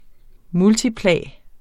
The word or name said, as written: multiplum